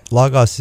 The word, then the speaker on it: Logos: And Logos is spelled L-O-G-O-S, and it's Logos, because it's the soft O's in Greek.